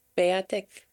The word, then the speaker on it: Peyotik: I don't know how to pronounce it. It's Cree. Peyotik? – Peyotik. I've learned it to pronounce it Peyotik.